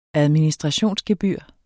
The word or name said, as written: administrationsgebyr